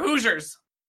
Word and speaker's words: Hoosiers: So I saw you using that term, and I'm like, is Hoosier. It pronounced hosier, or? – Yeah, it's spelled H-O-O-S-I-E-R, and it's literally what you don't call us Indianians, or Indiana Knights, or you call us Hoosiers. We're Hoosiers.